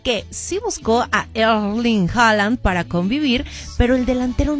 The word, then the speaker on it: Haaland: Bueno, ¿tú sabías que Holland, o bueno, Haaland, como quieras pronunciarlo, rechazó a Tom Holland, o sea, Spider-Man, y lo dejó en visto tras invitarlo a salir?